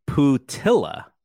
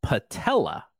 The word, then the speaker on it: Putila: Now his name phonetically, it looks like you would pronounce his name Putila, but from what I understand, it's Patella, like the oh, kneecap.